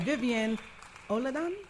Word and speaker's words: Vivian Olodun: And today in the house, we have a beautiful, lovely, talented power player, Vivian Olodun. – Yes. – I said it right? – Yes, you did.